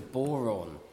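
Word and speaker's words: bodhrán: So this is an Irish drum, and it's called a boron. And it's spelt in a quite a peculiar way. It's spelt B-O-D-H-R-A-N.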